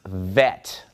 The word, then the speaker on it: Vet: But if we remove the dot from the middle of Bet, now it becomes pronounced vet, like a V sound.